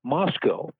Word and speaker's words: Moscow: I had an instructor who also did weekend news on a station in Indianapolis tell me repeatedly, there are no cows in Moscow. It's pronounced, at least to her imagination, Moscow.